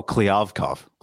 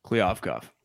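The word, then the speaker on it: Kliavkoff: How do you say his last name? – Who's George? Last name? Oh, Kliavkov. – Kliavkov. – Yeah, Pac-12 commish.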